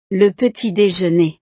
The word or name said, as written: le petit dejeuner